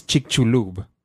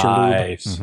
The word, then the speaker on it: Chicxulub: For those of you who are listening, if you'd like to know how to pronounce the name of that crater, it's Chicxulub. – Nice. Chicxulub.